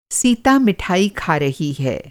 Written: मिठाई

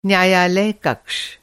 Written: कक्ष

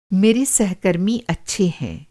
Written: सहकर्मी

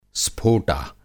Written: स्फोटा

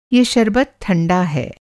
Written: शरबत

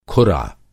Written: खुरा

